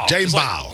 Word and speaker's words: Jaden Ball: How do I say it? Ball. Jaden Ball.